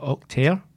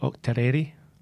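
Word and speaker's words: Autechre: I call it Octaire. Some other people call it Octaireri.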